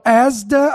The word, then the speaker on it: Asda: One grocery chain there called Asda, I believe that's how you pronounce it, ASDA, have been told that they can buy up to 3 packs.